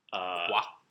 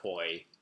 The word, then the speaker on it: Quoi: Quoi. – Because it sounds like it could be French. Yeah. Or, if you read it, I was pronouncing it Koi for a long time until I was corrected.